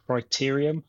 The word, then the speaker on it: Criterium: And the Crit is short for Criterium. Am I saying that right if you're a cyclist, yeah, Ben? – That's right.